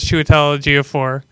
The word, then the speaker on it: Chiwetel Ejiofor: It is Chiwetel Ejiofor.